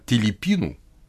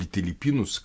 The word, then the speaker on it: Телипину: Но, начиная с правления царя Телипину, или Телипинус, как иногда его произносят,